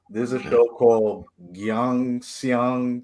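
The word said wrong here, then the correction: Gyeongseong: Creature. I forgot how to pronounce it. – Oh yeah, Young. – I think I something saw that wrong. – Something. Let me look it up real quick. I'm butchering the shit out of that name. I'm sorry. Hold on. Yeah, Gyeong Seong Creature. Yeah, that's how you say it.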